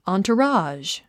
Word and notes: entourage: ὰːnturάːʒ; アントラージュ

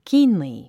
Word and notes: keenly: kíːnli; キーンリィ

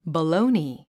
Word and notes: baloney: bəlóuni; バロゥニィ

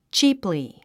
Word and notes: cheaply: tʃíːpli; チープリィ

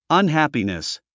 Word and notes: unhappiness: ʌnhǽpinis; アンハピネス

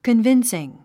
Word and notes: convincing: kənvínsiŋ; カンビンスィン

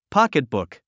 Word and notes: pocketbook: pɑ́kitbùk; ポケトブク

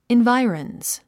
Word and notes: environs: invái ə rənz; インバイオランス

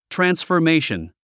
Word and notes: transformation: træ̀nsfə r méiʃən; トランスフォメィション

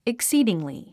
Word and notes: exceedingly: eksíːdiŋli; エクスィーディングリィ

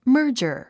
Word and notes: merger: mə́ː r dʒə r; マージャァ

